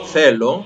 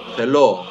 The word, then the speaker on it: θέλω: Όταν δηλαδή εγώ θα πω θέλω, αντί να τονίσει η μελωδία το θε, που είναι ο τόνος, τονίζει το λο, θελώ, το οποίο είναι λάθος.